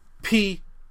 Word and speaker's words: Prowse: But David Prowse, I believe I pronounced his name, Prowse. – Sounds like Prowse.